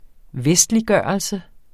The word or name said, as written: vestliggørelse